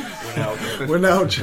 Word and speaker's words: Wijnaldum: Wijnaldum.